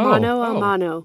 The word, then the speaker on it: mano a mano: No, it's the letter A, but how do you say it? – Oh. Mano a mano. – Mano a mano. Okay.